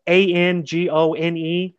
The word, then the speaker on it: Angone: Uh, my middle name's Anthony, so I could be Tony Angone is actually the pronunciation, you know, of this very Italian name.